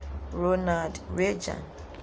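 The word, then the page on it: Ronald Reagan: Twi; /ˈreɪɡən/; RAY-gən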